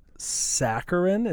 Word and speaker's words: saccharin: Yes, I'm drinking Filberts, which mine is using sodium saccharin. Is that how you say it, Matt? – Yeah, that sounds right.